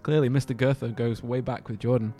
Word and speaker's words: Goethe: Goethe. – Goethe. Oh, he's Goethe. He's a girthy boy.